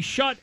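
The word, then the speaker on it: Schutt: The NFL did not include the, how do you say this, the shut air advantage? It's shut.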